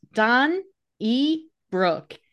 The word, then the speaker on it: Donnybrook: And now, without further ado, today's word of the day is Donnybrook, spelled Donnybrook, pronounced Don E Brook.